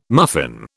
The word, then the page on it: muffin: British English